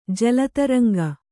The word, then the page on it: ಜಲತರಂಗ: jala taraŋga